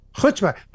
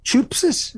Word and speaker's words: Chutzpah: Chutzpah. By the way, thank you very much for saying that correctly.